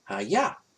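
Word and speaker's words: Hayah (חיה): The word lived in this verse is Strong's H2421 and is pronounced Hayah. Hayah. And it means to live.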